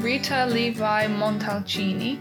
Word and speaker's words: Rita Levi Montalcini: This is a name that I can't pronounce well, but I'll give it a try. So she's called Rita Levi Montalcini. – Nailed it.